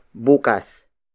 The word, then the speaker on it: bukas: And then we have bukas. Bukas. Bukas. Bukas is spelled as B-U-K-A-S.